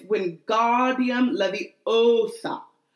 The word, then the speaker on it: Wingardium Leviosa: You're saying it wrong. It's Wingardium Leviosa. Make the gar nice and long.